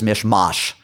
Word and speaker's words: mishmash: That was Mish Mosh. From the central Jersey suburbs, fast closing in on age 65, Karen Winograd. Dear Unorthodox, it's mash like potatoes in the TV show.